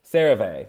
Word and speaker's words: CeraVe: And so I am a big fan of, I can never pronounce it. It's like CERVE. – CeraVe? – CeraVe. – Yes.